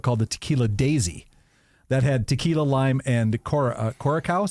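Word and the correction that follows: Curaçao: is that how you say it? Or Caraco? How do you say that? Curacao. – Is that right? – That sounds more right.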